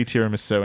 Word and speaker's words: tiramisu: The pronunciation is definitely tiramisu, and anyone who says it otherwise is wrong.